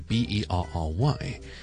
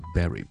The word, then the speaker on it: bury: berry 咧就系我哋讲嘅好似草莓类嘅生果啦，例如 strawberry 啦、 blueberry 啦、 blackberry 啦、 raspberry 啦之类个 berry b e r r y，而另外一个咧就系个动词埋葬 to bury somebody 嘅 berry b u r y， and they have exactly the same pronunciation， both are berry。